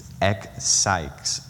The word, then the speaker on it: ekpsyches: It's ekpsyches, E-K-P-S-Y-C-H-E-S, meaning from the soul.